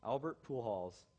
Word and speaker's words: Albert Pujols: Albert Pujols.